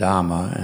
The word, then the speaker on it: dhamma: In Pali, those words are pronounced nibbana, kama, and dhamma, and there's a lot of other examples of that.